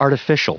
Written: artificial

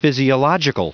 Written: physiological

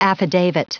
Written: affidavit

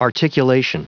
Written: articulation

